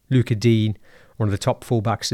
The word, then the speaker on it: Digne: Coutinho and Dina, you know, I think it's, or Dean, sorry, Luca Dean is how it's pronounced apparently.